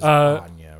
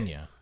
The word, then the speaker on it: Enya: It was Anya, right? – Whatever, however Enya. You pronounce it. – No, it's Enya.